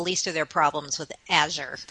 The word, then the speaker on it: Azure: I always say Azure. Rhymes with badger.